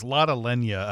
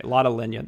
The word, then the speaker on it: Lotte Lenya: I don't know how to say her first name. It's Lotte Latte. – Yeah, I actually heard this name pronounced several times, but I don't remember if it's exact, but it's Lotte Lenya, I think. – Okay, Lotte Lenya. That makes sense.